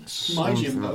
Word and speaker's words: Yojimbo: Or Yojimbo, I don't know how you pronounce it. – Yojimbo? – Yojimbo. – Like that.